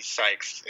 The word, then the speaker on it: Sykes: Sykes, and Sykes is spelled S-Y-K-E-S.